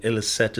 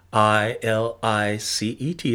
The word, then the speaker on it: Ilicet: Ilicet is spelt I L I C E T. It is a Latin word. I L I C E T. Ilicet.